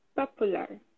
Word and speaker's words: popular: Popular. – Almost there. With that one, we say popular. Popular. – Popular. – A gente... Yes, exactly. Popular. And not popular. Not popular.